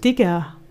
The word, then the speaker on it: Digga: Es ist kein IE, aber es ist auch kein ZK. Wie spricht man das aus? – Digga.